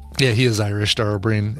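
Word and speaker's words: Dara Ó Briain: It's Dara O'Brien, but it's spelled B-R-I-A-I-N, I think.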